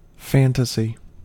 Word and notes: fantasy: American English

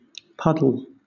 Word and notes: puddle: British English

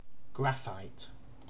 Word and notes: graphite: British English

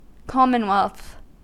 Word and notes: commonwealth: American English

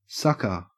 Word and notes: sucker: Australian English

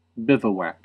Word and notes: bivouac: American English

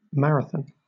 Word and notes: marathon: British English

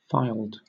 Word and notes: filed: British English

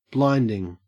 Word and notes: blinding: Australian English

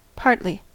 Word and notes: partly: American English

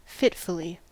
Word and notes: fitfully: American English

